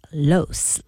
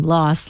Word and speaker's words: Los: Jamie Lee pronounces it Los, like rhymes with gross. Mariska is team Los, like boss.